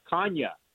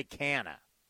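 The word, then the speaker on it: Canha: The correct pronunciation is for Kanya. It's Mark Kanya. I don't know if you saw that or not. – Okay, no, we play. You know, he just, yeah, but his family, they go by Canna.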